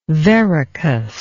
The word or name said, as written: verrucous